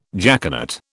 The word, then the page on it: jaconet: British English